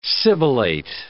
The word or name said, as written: sibilate